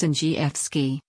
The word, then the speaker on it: Andrzejewski: Andrzejewski, pronounced Andrzejewski, came to prominence in Illinois during the Tea Party movement and soon launched a short campaign for governor in 2010.